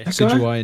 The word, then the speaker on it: Decidueye: Decidueye? – Yeah, Decidueye indeed.